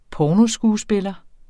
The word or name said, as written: pornoskuespiller